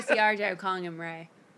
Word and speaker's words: Ralph: Ra- it's pronounced Ray. Ray. – Well, it's spelled Ralph.